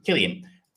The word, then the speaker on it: Cillian: Cillian.